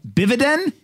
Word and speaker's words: bividend: First of all, how do you pronounce the word? It's not a dividend. It's a bividend. Is that what you guys call it? – It's a bividend.